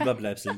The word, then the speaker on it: Überbleibsel: Übelbleibsel, so gut, ich hoffe, ich habe es richtig ausgesprochen. – Überbleibsel, ja, ist korrekt.